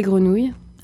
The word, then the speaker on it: grenouille: Elle chantait les grenouilles.